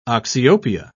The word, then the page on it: oxyopia: British English